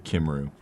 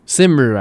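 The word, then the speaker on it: Cymru: Like, wait, is it CMRU or is it Kimru? – Simru, actually.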